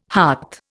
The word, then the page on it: hart: Indian